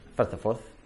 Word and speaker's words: Firth of Forth: And it's famous because, that's how you say it, I think, in Scottish. Firth of Forth.